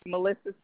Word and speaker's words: Melissa: Melissa Simmons, which is spelled M-E-L-I-S-S-A. Simmons, S-I-M-M-O-N-S.